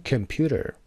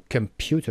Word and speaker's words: computer: کلمه بعدی computer سه‌سیلابیه، ببینید computer. So computer قسمت پیو.